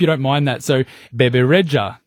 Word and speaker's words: Bebe Rexha: Yes, Bebe Rexha.